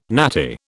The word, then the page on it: natty: British English